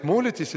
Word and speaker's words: молитесь: Итак, как вы думаете, сейчас стоит молитесь или молитесь? Одно ударение. Или вы на, мо, на О поставите, или на И поставите. Будет, молитесь, это будет настоящее время, молитесь, это будущее.